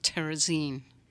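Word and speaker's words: Terezín: We visited a Nazi concentration camp about 25 miles outside of Prague, and it was called, it's called Turezin, T-U-R-E-Z-I-N, I believe it's spelled. – Or they pronounce it Terezin.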